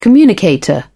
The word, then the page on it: communicator: British English